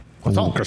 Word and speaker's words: croissant: Croissant. – Two weeks ago. – Isn't it great? – The tea is silent.